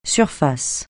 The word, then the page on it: surface: French